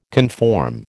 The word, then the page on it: conform: American English